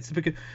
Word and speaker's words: seppuku: He's a giant fan, Force fanboy, like, and when he commits seppuku, right? That's how you say it, seppuku, with a lightsaber.